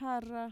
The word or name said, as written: jarra